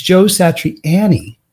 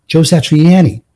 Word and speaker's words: Joe Satriani: I used to call him Joe Satriani, but then when I did research for the actual podcast, when I interviewed him, it's Joe Satriani. That's the way he calls it, Joe Satriani. So I've been saying it wrong for all these years.